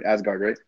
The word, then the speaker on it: Asgard: Asgard, Yeah, that's good.